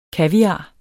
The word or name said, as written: kaviar